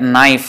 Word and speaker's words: knife: And words like knife, for example, the K is silent.